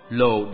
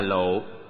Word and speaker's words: lộ: Cam là ngọt, lồ đọc đúng là lộ, nghĩa là xương.